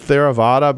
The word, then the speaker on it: Theravada: You've mentioned uh, several times that we're talking about a particular kind of of Buddhism, uh, and I'll take a shot at pronouncing it Theravada, but that, I'm, I'm, sure I've got that wrong. Uh, you got uh, it right.